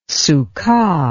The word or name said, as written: sukkah